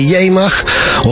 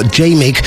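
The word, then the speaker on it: Jemig: If you're a new listener, you're always wondering what the hell is a Yemach or Jamig, as it's spelt in the wonderful Dutch language.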